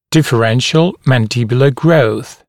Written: differential mandibular growth

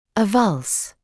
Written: avulse

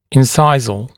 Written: incisal

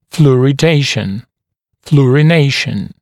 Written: fluoridation, fluorination

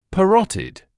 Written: parotid

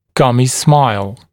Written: gummy smile

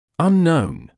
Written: unknown

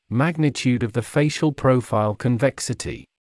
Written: magnitude of the facial profile convexity

